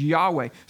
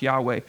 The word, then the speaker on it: Yahweh: They don't even put the vowels in this, so we don't actually know how it would be pronounced, but Yahweh seems to be the way it would be.